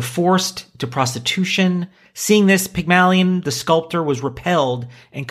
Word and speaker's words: Pygmalion: But it starts with, in ancient Greek mythology, Pygmalion, is that, am I saying that right? – Yep.